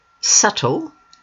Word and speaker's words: subtle: Subtle, note that the B is silent, means slight, not strong, difficult to be sure.